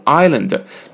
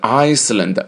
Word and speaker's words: island: On Swan Island，注意了，天鹅不念 swan，也念 swan 啊， A 再次是 o 的音， swan island，注意 island 是小岛，那么这个岛 s 是不发音的，不要念成 Iceland， no no no， island 就是岛啊。